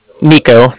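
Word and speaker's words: Niko: Nico. That's N-I-K-O.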